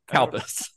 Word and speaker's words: Calpis: C-A-L-P-I-S. When I wrote it down, I was like, the way that this is going to sound when an American pronounces it is that it's going to sound like cow piss. But it's cow piss. It looks like milk. – Kalpis, C-A-L-P-I-S.